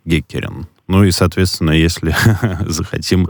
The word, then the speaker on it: Геккерн: А как, кстати, правильно произносить фамилию? Это тоже русифицированный вариант Геккерен? – Ну, мы произносим Геккерен, хотя иногда говорят о Геккерен, как будто бы два чтения возможны. – А Геккерн, когда теряется буква «Е»? Вроде как это ближе к голландскому.